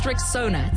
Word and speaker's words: Rexona: It's pronounced Rexona. It's simple, strong, and easy to remember.